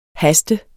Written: haste

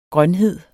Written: grønhed

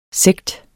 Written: sekt

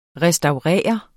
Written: restaurere

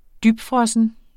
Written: dybfrossen

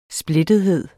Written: splittethed

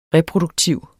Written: reproduktiv

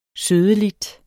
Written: sødeligt

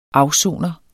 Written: afsoner

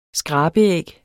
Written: skrabeæg

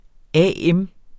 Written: AM

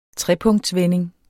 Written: trepunktsvending

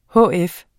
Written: hf

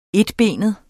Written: enbenet

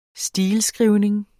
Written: stileskrivning

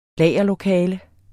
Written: lagerlokale

